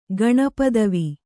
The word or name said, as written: ಗಣಪದವಿ